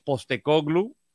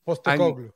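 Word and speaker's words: Postecoglou: Fue muy interesante la semana pasada cómo hablaron de esta táctica de, de Angelos Postecoglu. ¿Lo dije bien? – Sí, sí, sí, sí. Eh, Postecoglu. Postecoglu.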